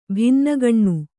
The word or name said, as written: ಭಿನ್ನಗಣ್ಣು